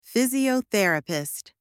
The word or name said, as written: physiotherapist